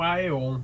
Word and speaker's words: Aeon: É o novo Ion. – Nossa, eu nunca prestei atenção nisso. – Ele fala Aeon, né? – É. – A música, se não me engano, ele pronuncia como Aeon.